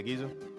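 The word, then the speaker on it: Ghezo: The real king Gizo, Gizo, oh, am I saying that right? Yeah, yeah, Gizo.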